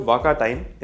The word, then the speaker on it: WakaTime: So it's called Waka Time.